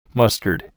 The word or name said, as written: mustard